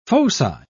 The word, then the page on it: foci: British English